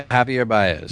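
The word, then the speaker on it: Javier Báez: It is Javier Baez. Am I pronouncing that right? – Yeah, Javier Baez.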